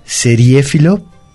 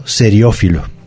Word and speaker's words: seriéfilo: Es seriéfilo y no seriófilo. – Ah, ok. – Seriéfilo es la palabra, se escribe con acento en la segunda E y es un solo término, seriéfilo.